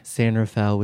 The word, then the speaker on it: San Rafael: I did not know that's how you pronounced it. – Oh, really? It's San Rafael? – I would have absolutely said Rafael. – I mean, I'm sure that's the correct pronunciation, but the gringo, now everyone just calls it San Rafael.